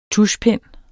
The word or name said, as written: tuschpen